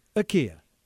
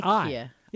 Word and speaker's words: IKEA: It's according to their website, according to their officials, it's IKEA. It's not IKEA.